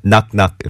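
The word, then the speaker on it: Knock Knock: 첫 곡은 트와이스의 넉넉 이거 발음을 우리 권수림 PD가 넉넉한 휴일 보내세요 이런 뜻으로 넉넉한 거냐고. 예. 원래 저, 저 가사에는 낙낙 이렇게 원어 발음으로 나오지만 아 이게 저한 우리 우리 외래어 발음과 예, 어, 원어 발음과 섞기가 힘드네요. 네. 트와이스는 어쨌든 낙낙한 걸로.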